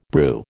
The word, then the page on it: brew: American English